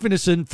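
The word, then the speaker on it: Ineson: Ralph Innison is how you say it.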